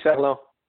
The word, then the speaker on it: Ferland: So it's Danny Ferland.